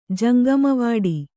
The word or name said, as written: ಜಂಗಮವಾಡಿ